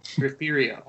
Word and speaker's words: Refugio: How do you say the name of your hometown? – Refugio.